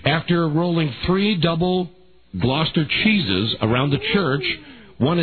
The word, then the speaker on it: Gloucester: Yep, Gloucester.